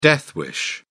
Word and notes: death wish: British English

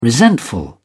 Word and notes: resentful: British English

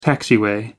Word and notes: taxiway: American English

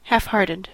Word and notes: halfhearted: American English

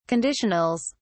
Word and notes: conditionals: British English

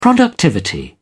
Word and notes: productivity: British English

